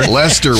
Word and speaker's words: Leicester: Leicester, we know now.